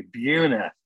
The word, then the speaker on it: Buhner: I think it's Buhner, Buehner. – That's German. – Yeah, how is it pronounced? Do you know? – Yeah, the U would have an umlaut, so it would be Buhner.